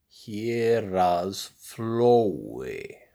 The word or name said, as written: Héraðsflói